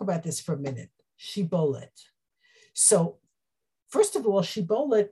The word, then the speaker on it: shibboleth: They said, say Shibboleth. And they said, Sibboleth. They could not pronounce the shin.